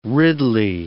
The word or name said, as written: ridley